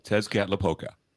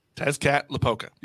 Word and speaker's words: Tezcatlipoca: Tezcatlipoca. – Yeah, Tezcatlipoca. – Tezcatlipoca.